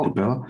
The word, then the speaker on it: Capella: Because now we have a fully cloud managed solution called Capella.